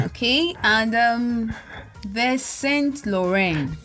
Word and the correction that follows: Saint Laurent: Saint Laurent.